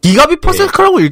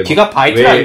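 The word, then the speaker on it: Gbps: GBPS가 기가빗 퍼 세컨드라고 읽지도 못하죠. GBPS가 뭐예요? 이러겠지. – 예. 그리고 이제 기가바이트라 읽을걸요? – 왜 맞아요.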